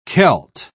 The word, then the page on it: kelt: British English